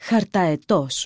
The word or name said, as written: χαρταετός